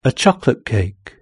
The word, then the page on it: a chocolate cake: British English